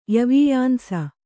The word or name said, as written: ಯವೀಯಾಂಸ